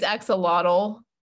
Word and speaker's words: axolotl: It's axolotl.